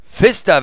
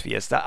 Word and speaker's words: Vista: Nein, es heißt Vista, wenn es auf den Markt kommt. Derda, vielen Dank dafür. Ich habe es immer falsch gesagt. Ich habe es auch gedacht, es heißt Fiesta, aber es heißt Vista.